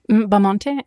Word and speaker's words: Bamonte: Perry Bermond. – Bamonte, ja.